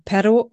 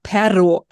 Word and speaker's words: perro: das Doppel-R wird auch anders ausgesprochen als das einfache R. Also man hört das dann Perro oder Perro, also Hund oder Aber.